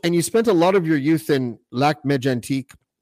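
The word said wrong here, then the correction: Lac-Mégantic: You butchered that. Mégantique. Mégantique. – And now auditioning for the role of the guy who can't speak French. Sorry, how do you say it? – Lac Mégantique.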